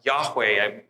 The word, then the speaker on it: Yahweh: So that those four letters, which, you know, people pronounce Yahweh, we don't know for certain that's the way it was pronounced, but that's our best guess.